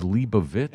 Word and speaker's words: Leibovitz: Correct pronunciation: Annie Leibovitz. – Oh no! – And I'm like, you just fucked my charity, man. – He didn't do that. – He did. – The correct pronunciation is Leibovitz, and I didn't get the money for it.